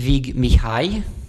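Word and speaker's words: Vig Mihály: Und du hast, ich werde es natürlich falsch aussprechen, aber du kannst mich korrigieren, Vic Mihaly mitgebracht. Ist das richtig ausgesprochen? – Fast. Ja, aber Ungarisch ist nicht die leichteste Sprache. – Definitiv. – In diesem Sinne, in keinem Sinne eigentlich. Ja, er ist Vig Mihai.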